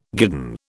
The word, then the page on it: guidon: British English